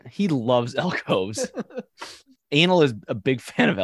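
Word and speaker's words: Anıl: A-N-I-L-K-O-C. But the article I read literally said, even though it's spelled with an I, it's pronounced as an A. – Like... We could pronounce it Anil, but let's make things really hard.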